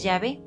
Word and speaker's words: Yahvé: Entonces, la mejor traducción, como se lee, es Yahvé.